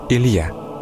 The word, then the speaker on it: Илья: В современном русском языке имя Илия обычно произносится как Илья.